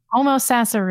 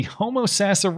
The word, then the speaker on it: Homosassa: Homosassa River. – The Homosassa River in Florida.